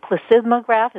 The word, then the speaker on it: plethysmograph: I want to get back to the plethysmograph.